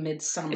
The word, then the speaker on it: Midsommar: It's actually Midsommar. – It's Midsommar. – Don't ask me why. When I looked at it, I just started pronouncing it like that. And everybody's like, that's not how you say it.